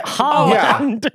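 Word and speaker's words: Haaland: It's pretty phonetic. It's just Holland.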